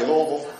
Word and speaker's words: Louisville: Did I say that right, Louisville? Y'all didn't even shout on that. I said Louisville right.